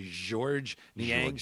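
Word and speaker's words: Georges Niang: Yeah, like it's George, but with a Z-8, like George Niang.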